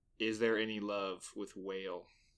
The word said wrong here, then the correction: Wale: Yeah, it's pronounced Wale.